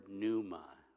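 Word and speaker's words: pneuma (πνεῦμα): You pronounce the second word pneuma, okay? The P is silent, right?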